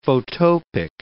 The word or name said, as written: photopic